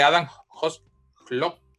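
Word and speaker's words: Adam Hložek: Estamos hablando de Adam Hoslock. – Jugadorazo. sí, lo, pronunciaste bien.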